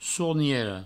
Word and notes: sorniera: sourniero

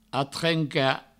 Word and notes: atrencar: atrenca

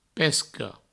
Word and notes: pesca: Provençal; pesco

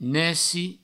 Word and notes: neci: nesci

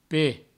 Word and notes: ped: provencal

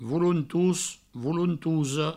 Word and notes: volontos: volontosa; voulountous; voulountouso

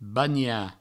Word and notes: banhar: bagna; Provençal

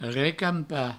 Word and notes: recampar: recampa

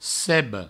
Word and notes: seba: sebo

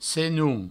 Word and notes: senon: senoun

